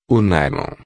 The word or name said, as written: nailon